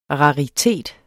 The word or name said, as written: raritet